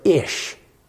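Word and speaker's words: ish (אִישׁ): In the Hebrew, you, you would pronounce it ish. Ish. That's the Hebrew for man.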